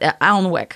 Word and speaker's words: Alnwick: Now, it's spelled A-L-N-W-I-C-K, and so I was going to call it Alnwick Garden, but the internets said that it was Alnwick, and to stop saying it that way.